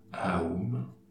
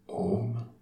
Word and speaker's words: Aum: Il s'agit du mantra Aum, A-U-M, parfois prononcé Om.